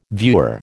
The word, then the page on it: viewer: American English